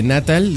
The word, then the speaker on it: Natal: Que no es Natal. – No, porque no tiene acento en la A.